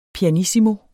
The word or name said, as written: pianissimo